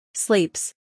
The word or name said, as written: Sleeps